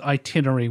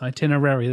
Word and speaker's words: itinerary: and he says itinerary, which just drives me mental. It's itinerary, there's an extra syllable there.